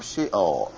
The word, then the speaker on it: Sheol: Then, following on from that, you have the promise that in so doing, you will deliver him from S-H-E-O-L. Deliver him from Sheol, or Sheol, as it should be probably pronounced.